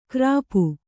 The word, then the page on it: ಕರ್ಪು: Kannada